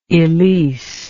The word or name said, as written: elyse